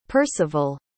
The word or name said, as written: perceval